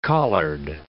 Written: collard

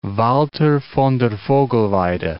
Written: Walther von der Vogelweide

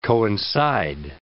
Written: coincide

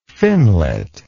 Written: finlet

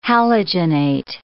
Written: halogenate

